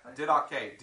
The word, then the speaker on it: Didache: Didache. D-I-D-A-C-H-E.